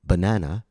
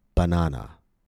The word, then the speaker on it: banana: The letter a will be reduced to the vowel schwa. B, na, na. A native speaker will not pronounce the word banana.